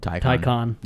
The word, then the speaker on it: Taycan: Taycan.